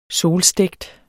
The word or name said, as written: solstegt